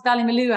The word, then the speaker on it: Ballymaloe: Well, just to tell you a little bit about, we pronounce it Ballymaloe, actually. Ballymaloe means the town's land of sweet honey.